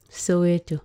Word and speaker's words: Soweto: Is that how you say it? – Soweto. – Soweto.